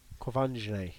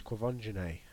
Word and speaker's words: Quvenzhané: Quvenzhané Wallace plays Nick Carraway. – Is that how you say her name? – Quvenzhané. Yes, Quvenzhané.